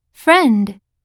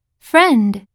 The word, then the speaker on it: friend: Friend. Friend. – この単語には IE という綴りがあるね。これはえと発音するよ。